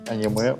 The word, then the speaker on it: аниме: На самом деле, если можно, хотел рассказать про свое знакомство с аниме. Кстати, да. А нам ответили на вопрос, куда правильно ударение ставить? – А, кстати, правильно. – Ты знаешь? – Я скажу аниме.